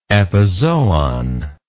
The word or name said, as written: epizoon